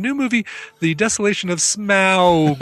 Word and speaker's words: Smaug: Speaking of the desolation of smug or smog. Mm-hmm. Smaug. – Smug. Smaug. – How do you pronounce it? – Well, it's supposed to be pronounced smaug.